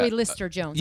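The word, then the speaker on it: Zoe Lister-Jones: Zoe Lister Jones. – Yeah, I believe that's how you say it.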